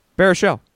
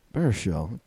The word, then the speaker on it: Baruchel: Baruchel. – Baruchel.